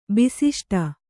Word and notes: ಬಿಸಿಷ್ಟ: bisiṣṭa

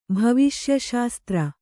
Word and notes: ಭವಿಷ್ಯಶಾಸ್ತ್ರ: bhaviṣya śastra

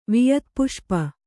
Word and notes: ವಿಯತ್ಪುಷ್ಪ: kannada

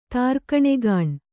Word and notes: ತಾರ್ಕಣೆಗಾಣ್: Kannada

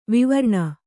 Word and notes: ವಿವರ್ಣ: vivarṇa

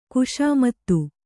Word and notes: ಖುಷಾಮತು: kannada

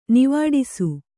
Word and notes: ನಿವಾಡಿಸು: nivāḍisu

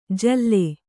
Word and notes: ಜಲ್ಲೆ: kannada

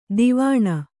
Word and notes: ದಾವಣ: kannada

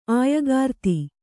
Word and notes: ಆಯಗಾರ್ತಿ: Kannada

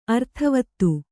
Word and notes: ಅರ್ಥವಾದ: arthavāda

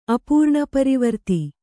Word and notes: ಅಪೂರ್ಣಪರಿವರ್ತಿ: apūrṇaparivarti

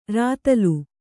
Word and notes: ರತಲು: kannada

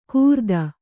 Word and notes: ಕೂರ್ದನ: kūrdana; kannada

